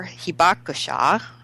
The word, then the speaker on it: Hibakusha: Kathleen is currently the program director for Hibakusha. I hope I said that right. Close, yeah. – I hope. – You did.